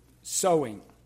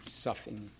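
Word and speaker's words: soughing: Some people pronounce that word sowing. It's S-O-U-G-H-I-N-G. But I grew up pronouncing it suffing.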